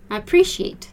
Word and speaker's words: appreciate: And just another way of saying thank you would be appreciate. So I appreciate your help. And pay attention to the pronunciation of appreciate. Appreciate, we have again that CH, that SH sound, okay?